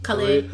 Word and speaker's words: Khalid: Also listen to, what is it? Khalid? – Khalid.